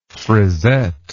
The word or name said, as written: frizette